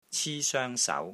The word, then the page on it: CI1 SOENG1 SAU2: Cantonese